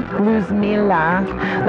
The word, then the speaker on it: Luzmila: Luzmila. Say that again. Luzmila. Like loose, like light, L-U-Z.